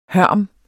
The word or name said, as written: hørm